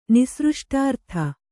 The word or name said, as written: ನಿಸೃಷ್ಟಾರ್ಥ